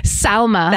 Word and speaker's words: Salma: Salma. – Salma. – Almost like Salma Hayek. – Oh. – S-A-L-M-A. – Salma.